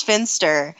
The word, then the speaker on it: Finster: Yeah, Miss Finster. Rhymes with spinster.